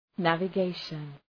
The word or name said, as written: navigation